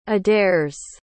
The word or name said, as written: aderes